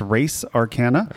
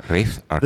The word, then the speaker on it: Res Arcana: This week's game review is Res Arcana, pronounced Race Arcana. – Race Arcana.